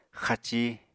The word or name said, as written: xaci